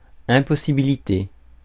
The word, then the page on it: impossibilité: [ɛ̃.pɔ.si.bi.li.te]; France